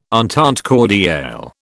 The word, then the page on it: entente cordiale: British English